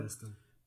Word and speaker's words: Princeton: Dean Taylor habla sobre Eileen Showalter, una profesora de inglés en, en Princeton, Sí, ¿verdad? Se pronuncia Princeton. Princeton.